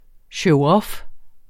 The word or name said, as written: showoff